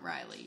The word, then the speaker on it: Rylie: But not Rylie, usually.